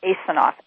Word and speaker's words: Asinof: If you'll go in and search on my name, Paula, P-A-U-L-A, last name Asanoff, A-S-I-N-O-F. – Oh, I'm mispronouncing it incorrectly. – No, fine. Don't worry about it. It's pronounced several different ways.